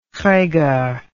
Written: reger

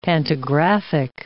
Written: pantographic

